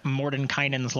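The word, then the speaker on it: Mordenkainen: Sophina announces the name of the magical lock, which is Mordekainen. Did I say that right? – Yep, Mordekainen's lock.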